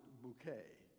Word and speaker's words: Bucket: The central character is an eccentric and snobbish lower middle class social climber, Hyacinth Bucket, who insists that her surname be pronounced Bouquet.